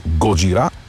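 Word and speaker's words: Годжира (ゴジラ): Годжира, това е японската дума за Годзила. Знаем, че групата започва именно под това име. Позволявам си да произнасям с френското ударение на последната сричка – Годжира.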